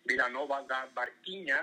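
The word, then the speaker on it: Vila Nova da Barquinha: Paso por una ciudad que se llama Vilanova da Barquiña, no sé si lo he pronunciado bien. – Sí, sí.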